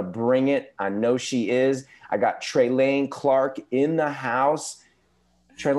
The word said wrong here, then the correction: Trelane: It's pronounced Trelane. – Trelane. – Times it can be tough for folks, but it's really actually very simple.